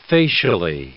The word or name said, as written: facially